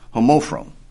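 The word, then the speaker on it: homophron (ὁμόφρων): This phrase of one mind is one Greek word, and it is spelled H-O-M-O-P-H-R-O-N-T-H-E. Again, that's H-O-M-O-P-H-R-O-N-T-H-E, and it's pronounced homophron. Homophron.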